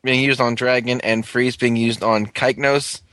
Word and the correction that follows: Cygnus: Or wait, no, that's not how you spell it. It's Cygnus.